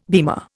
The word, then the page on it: beamer: Indian